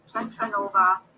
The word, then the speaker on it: TantraNova: TantraNova, you know, similar to supernova.